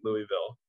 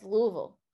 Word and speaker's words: Louisville: But I would love it if Tennessee somehow beat Louisville tomorrow afternoon. – You're saying it wrong. It's Louisville.